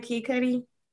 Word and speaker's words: Kid Cudi: Kid Cudi? – Mm-hmm.